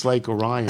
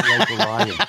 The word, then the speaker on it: Lake Orion: And then he simply writes without pronunciation, it's Lake Orion, not Lake Orion. It's Novi, not Novi. It's Mackinac, not Mackinac.